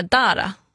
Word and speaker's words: Dara: Inte helt säker på uttalet där. Dara, så säger man.